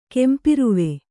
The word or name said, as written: ಕೆಂಪಿರುವೆ